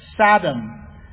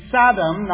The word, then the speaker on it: Saddam: he intentionally pronounced the president of Iraq name, the dictator, Saddam. He would intentionally say Saddam, not Saddam, because Saddam apparently is donkey.